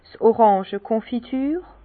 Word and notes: 's Oràngekonfiture: Bas Rhin; Herrlisheim

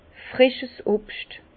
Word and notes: frischs Obscht: Bas Rhin; Herrlisheim

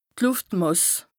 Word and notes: d' Lùftmàss: Bas-Rhin; Strasbourg

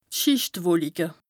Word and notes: d' Schìchtwolike: Bas Rhin; Strasbourg

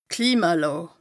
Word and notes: Klimalàà: Bas Rhin

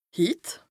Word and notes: hit: Bas-Rhin; Strasbourg

